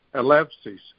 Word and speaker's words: Eleusis: Eleusis. – What's Alexis? – Eleusis. Eleusis. Eleusis. – Oh, Eleusis. Eleusis. – So I was pronouncing it in modern Greek, and you're taking it back to ancient Greek.